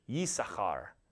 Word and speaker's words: Issachar: And you would have pronounced that Yisachar, so with a Y at the beginning, Yisachar, and the CH sound in there, you can see that.